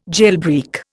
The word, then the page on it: jailbreak: Indian